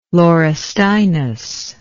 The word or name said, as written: laurustinus